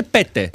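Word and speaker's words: Pete: Επειδή το έψαξα αρκετά, δεν προφέρεται πιτ στα φινλανδικά, προφέρεται Πέτε.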